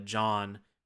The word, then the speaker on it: Jaune: Like one of the big things that you understand is that one of the characters, John, which is great because it's spelled like you're from Philly, J-A-U-N-E, you find out that he legally manipulated transcripts in order to get into right, the school, but no action takes place.